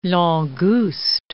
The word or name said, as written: langouste